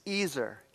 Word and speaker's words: ezer: And I'd like you to hold on to how it's pronounced. It's Ezer, E-Z-E-R.